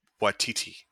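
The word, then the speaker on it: Waititi: His name is pronounced Watiti. – What did I say? – Watiki. You said it twice now.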